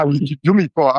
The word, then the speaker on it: Umicore: Ja, Umicore.